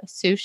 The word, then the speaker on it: sous: sous chef. And it is spelled S O U S and pronounced Sue.